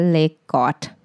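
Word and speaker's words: leg godt: Legot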